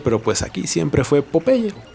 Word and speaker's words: Popeye: Cabe señalar que todo mundo en español conocemos a Popeye como Popeye, que no se pronuncia así porque su nombre en inglés es Popeye, de que le brinca el ojo.